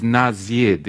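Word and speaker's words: nazid (נזיד): The Hebrew word here is nazid.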